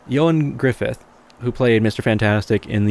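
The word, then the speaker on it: Ioan Gruffudd: And yes, his name is pronounced Ewan Griffith. – Okay. – Apparently. It's since he's Welsh, you know, and it's a little bit of different pronunciation, but, you know, it works.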